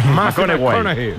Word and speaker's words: McConaughey: Hay que ir corrigiendo la pronunciación. – McConaughey. Matthew McConaughey. Bueno, pues el Mateo se pone.